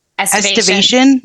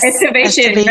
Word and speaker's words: estivation: Estivation. Yes. – Estivation.